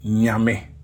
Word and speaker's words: Nyame: If we were to put a tree word together, a word that will come to mind would be nyame.